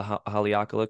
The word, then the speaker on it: Haleakala: But also, if you go all the way to the top of the crater, you could go to the top of the Haleakala crater, I'm pretty sure that's how you pronounce it.